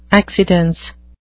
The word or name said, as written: accidence